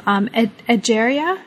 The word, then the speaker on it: Egeria: Egeria. Am I pronouncing that correctly? – Yeah.